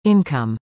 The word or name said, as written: Income